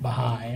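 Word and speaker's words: Bahá'í: I'm not familiar with Baha'i. Am I saying it right? – Mm-hmm.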